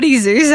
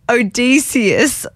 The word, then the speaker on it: Odysseus: It's not Oddie Zeus. I don't know where that came from. Guys, dyslexia is catching up to me. But Odysseus is how you say it.